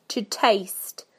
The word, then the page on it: to taste: British English